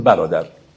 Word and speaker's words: برادر: به همین خاطر شک نکنید که مولانا برادر رو برازر تلفظ میکرده و زیبایی این مصرع اینه: ای برازر رو برازر بیدرنگ. ولی خب ما امروز دیگه چون تلفظمون عوض شده گفتیم برادر.